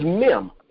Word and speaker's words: Mem: third Hebraic character is Mem, M E M.